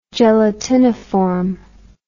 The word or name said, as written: gelatiniform